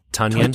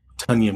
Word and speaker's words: Tonyan: Tonyan. – Tonyan? – You know, like Funyuns, but Tonyan. – Tonyan. Yeah, Tonyan with the T, Tonyan.